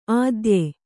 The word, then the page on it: ಆದ್ಯೆ: ādye